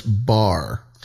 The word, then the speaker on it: Bohr: I thought I pronounced Bohr wrong. It's like, core? – No. You pronounced that right. No, I was just saying cool. Wait, what? – It's pronounced bar.